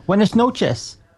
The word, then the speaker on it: buenas noches: Oh, buenas noches.